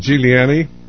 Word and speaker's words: Giuliani: Is it Giuliani? – Huh? – Lisa, am I pronouncing your name correctly? – It's Lisa Giuliani.